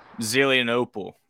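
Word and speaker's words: Zelienople: Is it Zenlope? – Zelianople. Yeah. – Yeah, wasn't going to pronounce that right.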